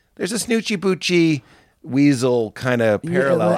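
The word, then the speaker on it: Snootchie Bootchies: But with like Snoochie Boochie, am I saying that right? – Yes.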